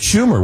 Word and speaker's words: Schumer: That was Chuck Schumer, was it not? – Yes. Yes. Yeah. His, yeah. The yeah. senator's name rhymes with rumor.